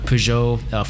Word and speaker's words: Peugeot: Lai gets in a Peugeot 406 taxi.